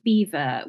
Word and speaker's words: Belvoir: And what could you just tell us the story about why we pronounce it Beaver when it's spelt B-E-L-V-O-I-R?